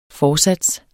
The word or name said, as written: forsats